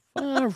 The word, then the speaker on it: Favre: He's like, that's Brett Favre.